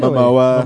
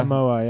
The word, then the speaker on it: Momoa: Is that how you say his name? Oh, really? Momoa. Momoa, yeah.